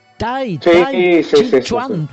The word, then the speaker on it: Tai Chi Chuan: Bueno, muy bien, Oscar, realmente, estás abriendo, uh-huh, uh-huh, estás abriendo camino al Tai Chi Chuan.